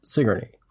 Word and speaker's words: Sigourney: I know it looks like Sigourney, but it's pronounced Sigourney.